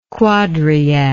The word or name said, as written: quadriad